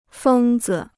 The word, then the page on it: 疯子: fēng zi